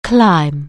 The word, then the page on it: climb: anglais